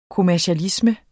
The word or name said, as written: kommercialisme